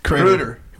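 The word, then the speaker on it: Credeur: I can't pronounce his name. What was it? Kreider? – Kreider.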